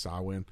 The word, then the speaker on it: Samhain: You know what's funny is this is like the only week of the year that I remember that it's pronounced Samhain. The rest of them I'm like, yeah, Samhain's coming around.